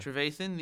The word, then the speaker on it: Trevathan: The most recent headline that I looked at this morning was the Jani Trevathan. I think that's how you pronounce his last name. – Trevathan, the Bears.